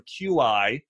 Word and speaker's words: qi: And here's what qi is. It's spelled QI qi in Chinese medicine is essentially is how full is your battery?